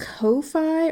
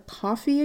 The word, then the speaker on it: Ko-fi: It's called a Ko-fi or Ko-fi account. It's spelled K-O-F-I.